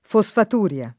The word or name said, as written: fosfaturia